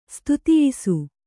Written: ಸ್ತುತಿಯಿಸು

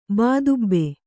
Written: ಬಾದುಬ್ಬೆ

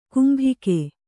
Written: ಗುಂಬಿಗ

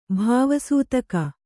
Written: ಭಾವಸೂತಕ

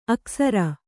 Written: ಅಕ್ಸರ

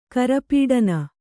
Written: ಕರಪೀಡನ

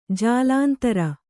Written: ಜಾಲಂದ್ರ

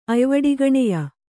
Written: ಅಯ್ವಡಿಗಣೆಯ

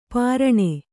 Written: ಪೈರಾಣ

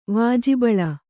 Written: ವಾಜಿಬಳ

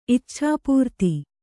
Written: ಇಚ್ಛಾಪೂರ್ತಿ

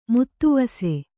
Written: ಮುತ್ತುವಸೆ

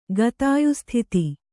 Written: ಗತಾಯುಸ್ಥಿತಿ

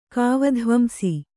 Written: ಕಾವಧ್ವಂಸಿ

